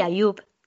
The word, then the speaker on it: Ayub: Es Airad y Ayub, ¿cómo se pronuncia? – Airad y Ayub, Ah, toma. – como lo has dicho, sí.